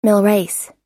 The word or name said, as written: milreis